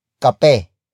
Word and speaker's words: kape: And that is kape. Kape. Kape. Kape is spelled as K-A-P-E.